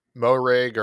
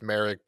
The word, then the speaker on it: Moehrig: It's either Moehrig or I don't think it's Merrick, but some people like drop the O when they announce it.